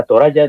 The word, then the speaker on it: Toraja: Como los Tana Toraya, que es lo que significa el país de los Toraya, Toraja, se escribe Toraja, pero se pronuncia Toraya, en español sería con Y.